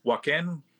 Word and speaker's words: Wakan: Wakan, Wakan, Wakan, like Wakanda with a duh at the end, like yep.